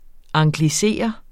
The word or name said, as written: anglisere